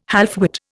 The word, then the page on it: halfwit: Indian